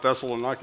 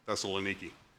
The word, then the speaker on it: Thessaloniki: So when we look at the town of Thessaloniki, is that how you say it? John Bevel corrected me after they got back from their trip. I said something about Thessaloniki. He said, Thessaloniki.